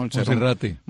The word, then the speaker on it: Monserrate: ¿Se pronuncia así? Monserrat, ¿no? – Monserrate. – Monserrate.